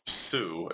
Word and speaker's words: Seu: And that's spelled A-N-D-R-E-E. Andre Sue is spelled S-E-U.